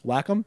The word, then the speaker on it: Wacom: Is that how it's pronounced? – Wacom? Yeah. – Okay. – People think it's Wacom. Nope. – It just sounds now. – It sounds dumb. – Like mole is the next word. Yep. Whack-a-mole.